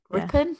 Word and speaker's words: Ripon: But that's Hereford, Inverness, Ripon. I don't know if I've just pronounced that right. – Ripon. – Yeah. Ripon.